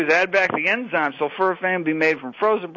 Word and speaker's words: sulforaphane: Now, can we talk, I don't even know if I'm going to pronounce this correctly, sulforaphane. – Sulforaphane, yes.